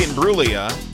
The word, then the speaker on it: Imbruglia: It's Natalie Imbruglia, but I think the first couple of times I said Imbruglia. Then the boss had to write on the music log, the G is silent.